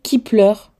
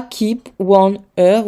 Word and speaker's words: Kep1er: Et en fait, c'est Kipler et pas Keep One Ear ou Keep One Ear, c'est Kipler.